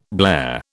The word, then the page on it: blare: British English